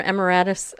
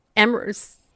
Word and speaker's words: emeritus: Pilley, a professor emeritus of emeritus.